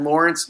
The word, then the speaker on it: Laurence: All right, they can follow me on Twitter at Laurence W. Holmes, and my first name is spelled L-A-U-R-E-N-C-E, and a lot of people get that wrong.